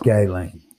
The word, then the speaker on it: Ghislaine: It's pronounced Ghislaine. – Is it Ghislaine? – Yeah.